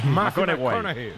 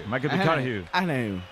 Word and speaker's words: McConaughey: Hay que ir corrigiendo la pronunciación. – McConaughey. McConaughey.